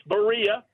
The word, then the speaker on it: Berea: Southside at Berea? Did I get that right? – You were close. You were close. I like – it's Berea, but you can pronounce it however you want.